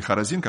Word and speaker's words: Хоразин: Горе тебе, Хоразин! Или Хоразин, как он читается.